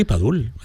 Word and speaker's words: Padul: Sí, sí, Padul.